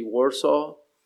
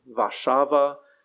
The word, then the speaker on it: Warszawa: But on this map, you'll see in the center, almost in the heart of Poland, you'll see Warsaw. That's the English pronunciation. Warszawa would be the Polish pronunciation.